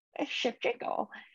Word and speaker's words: Shevchenko: Shevchenko.